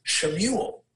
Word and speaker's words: Shemuel: You know, for example, Samuel is Shemuel in Hebrew. That's how it would be pronounced in Hebrew.